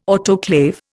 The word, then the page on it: autoclave: Indian